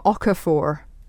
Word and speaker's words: Okafor: He plays William Okafor.